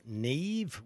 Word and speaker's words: Niamh: N-I-A-M-H is pronounced Neve.